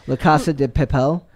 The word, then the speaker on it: La Casa de Papel: I can't pronounce the La Casa de Papel.